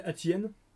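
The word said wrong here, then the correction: Atiyeh: Atié. – Voilà, j'avais peur de dire de la merde. – Atié. Là, Atié, hein, pour moi.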